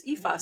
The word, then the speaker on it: Aoife: They're like two easy ones, but there's a Aoife, and her name is spelled A-I-O-F-E. – Yeah. – But I'm like, thank God for that, because now I know her name's Aoife.